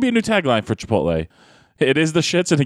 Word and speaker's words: Chipotle: They decide we're going to go eat at Chipotle.